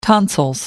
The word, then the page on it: tonsils: British English